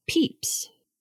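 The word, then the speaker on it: Pepys: I kept saying it as Pepis, and it's actually pronounced Peeps.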